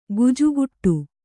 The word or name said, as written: ಗಿಜಿಗುಟ್ಟು